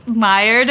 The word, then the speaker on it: Maillard: So with the fermentation time, I think it's part of the Maillard effect.